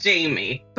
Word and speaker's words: Jaime: Your name is spelled J-A-I-M-E. Is it Jamie? – It is. It's Jamie. – But it looks like it could be French. J'aime, is it? – Yeah. So J-A-I-M-E is an alternative spelling. Normally J-A-M-I-E is more common, but my mom really loved the words I love in French, j'aime.